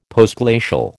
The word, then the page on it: Postglacial: American English